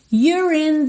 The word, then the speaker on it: you're: Se you contraído com R é your, e o E não é pronunciado, Aqui eu vou ligar então o R direto no in, you're in.